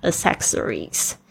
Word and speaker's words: accessories: 我们今天用的是复数形态，是后面去掉 y 加上 i e s 变成 accessories。 accessories，记住那个两个 c 发音都是不一样的哦，一个是发 k，一个是发 s，但是都是清音哦。 accessories。 Number nine，generic，generic，通用的 generic。 Number 10, statement. Statement, S-T-A-T-E-M-E-N-T, statement.